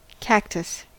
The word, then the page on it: cactus: American English